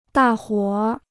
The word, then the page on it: 大伙儿: dà huǒ r